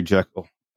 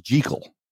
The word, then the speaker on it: Jekyll: There's a whole bunch of scenes, you know, with Muriel and Dr. Henry Jekyll. I'm sorry, it's not even Jekyll. It's correctly pronounced Jekyll, which is something I bet you didn't know. That's the correct pronunciation is Dr. Jekyll.